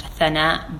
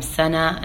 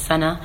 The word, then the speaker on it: Sana: So, my name in Arabic would actually be pronounced Thana, but when you take Indian culture, Pakistani culture, they pronounce the tha as a sa, mm-hmm. so it became Sana, and then just Sana.